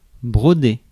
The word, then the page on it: broder: France; [bʁɔ.de]